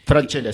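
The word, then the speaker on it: Francella: Franchella se pronuncia, creo, ¿no? – Franchella, exactamente, sí.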